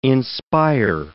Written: inspire

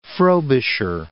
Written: Frobisher Bay

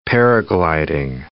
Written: paragliding

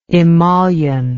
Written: emollition

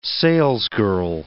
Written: salesgirl

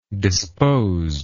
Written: disposedness

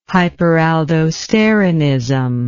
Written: hyperaldosteronism

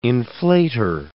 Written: inflater

